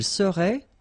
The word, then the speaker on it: serait: Il serait, final T not pronounced.